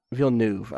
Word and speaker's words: Villeneuve: And I think that, Villeneuve, that's not how you pronounce it, is I never get his name right. – Villeneuve. I don't know. – It's Villeneuve.